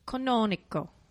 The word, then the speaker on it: Canonico: Canonico.